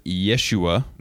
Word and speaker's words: Yeshua: So could you pronounce Joshua in Hebrew for us? – I'm going to go with Yeshua.